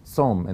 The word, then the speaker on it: tsom: It's pronounced the same, tsom.